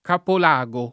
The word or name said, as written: Capolago